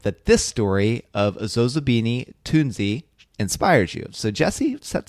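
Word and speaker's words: Zozibini Tunzi: No, Zozobini Tunzi.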